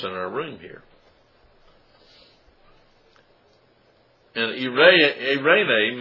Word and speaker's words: eirene: Now peace in this verse is a Greek form irene. Irene. And it's spelled E-I-R-E-N-E, probably where we get the common name Irene, which means peace.